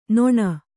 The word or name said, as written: ನೆಣ